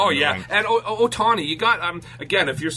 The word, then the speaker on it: Otani: I just, the other thing with Dusty in this one, the way he said Otani's name, he could never, I don't think he said it right once. – Otani.